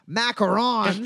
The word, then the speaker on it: macarons: You were able to buy, I think it's pronounced macarons, but I hate the French, as I am one.